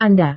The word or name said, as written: anda